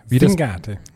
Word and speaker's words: Singate: Betonung auf I, Vergleich singen. – Singate.